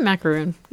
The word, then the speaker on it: macaron: Or is it pronounced macaron? Macaroon? Macaron? – Yeah, macaroon. – Macaroon. Okay.